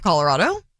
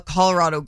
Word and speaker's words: Colorado: And I will back her a little bit because if you pronounce it in Spanish, it is Colorado.